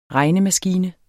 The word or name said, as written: regnemaskine